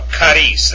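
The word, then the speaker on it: charis (χάρις): Now, with the accent over the alpha, charis, that's how you pronounce that.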